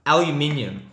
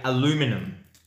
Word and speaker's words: aluminium: The word that you can't say, for those who don't know, which is very easy, aluminium. The Americans say aluminum.